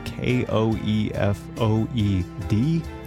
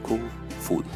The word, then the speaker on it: Koefoed: So here comes a pronouncer for Susanne Koefoed. Which I'd like to point out is spelled K O E F O E D. – Koefoed. – Let me say that again. Susanne Koefoed. – I do not hear the D in Koefoed.